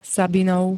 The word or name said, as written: sabinov